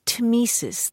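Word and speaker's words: tmesis: The other is temesis. That's T-M-E-S-I-S, temesis.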